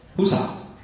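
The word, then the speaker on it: Pusa (菩萨): Pusa. There we go. Say Pusa. That's the Sanskrit, that the Chinese pronunciation of Bodhisattva.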